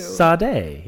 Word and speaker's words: Sade: Sade.